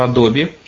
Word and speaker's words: Adobe: Есть такая американская компания Adobe, A-d-o-b-e и это читается Adobe, кстати, вот именно так.